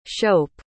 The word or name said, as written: shope